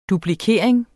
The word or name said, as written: duplikering